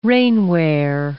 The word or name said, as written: rainwear